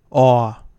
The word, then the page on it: aw: American English